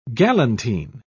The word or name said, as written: galantine